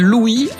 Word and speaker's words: Louis: Enfin, il paraît qu'il préférait qu'on l'appelle Louis à la française. Oui, parce que nous, en France, on prononce pas le S. Et il est arrivé qu'il sorte des disques avec un E à la place du S pour être sûr qu'on prononce son nom à la française.